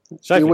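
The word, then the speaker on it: Scheifele: Shifley. – I Winnipeg. Shifley, ja, men den sätter jag. Den har jag lärt mig. Ja. – Mark Shifley. – Ja, där satt den. Så där ska man säga.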